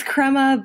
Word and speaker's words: Crema: Did I say it right that time in Kansas City? – It's Crema, but at least you didn't call it Crema Labs.